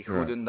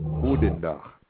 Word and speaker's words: goedendag: There is a G in it. If you only pronounce an H, it doesn't sound like the sound that we make if we say goedendag.